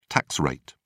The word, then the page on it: tax rate: British English